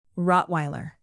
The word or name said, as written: rottweiler